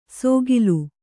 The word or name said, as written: ಸೋಗಿಲು